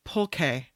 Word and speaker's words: pulque: It's pronounced pulque. Pulque.